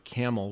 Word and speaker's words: Campbell: I say Youngstown, but I grew up in a city called Camel, which is, it's Campbell, but no one knows how to pronounce anything there. So so it's like spelled like the soup.